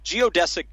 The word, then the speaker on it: Geodesic: It's called, gosh, I hope I say this right, Geodesic Dome Piece. I know how to say dome piece. Geodesic, I'm not sure if... – Yeah, that's right. – I said that right. Okay, all right. Geodesic Dome Piece is the record that came out earlier this year.